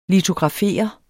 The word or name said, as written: litografere